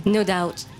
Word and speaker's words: No Doubt: No Doubt. – No Doubt. Ah voilà. – Le B ne se prononce pas.